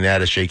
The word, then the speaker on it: attaché: Like Lynn Swann, they'd win a playoff game and he'd send everybody an attaché case. I don't know what the equivalent of that is now. – How do you say it? – Attaché. – Yeah, that's right. That's better that way.